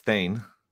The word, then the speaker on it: Thayne: I didn't hear you pronounce it, but it's Thayne.